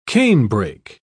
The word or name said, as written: canebrake